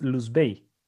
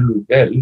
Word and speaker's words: Luzbel: En la novela, ¿quién o qué es Luz Bey? es el demonio, es, es, es, es la manera como ellos pronuncian Luzbel, ¿no es cierto?